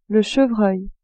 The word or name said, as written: chevreuil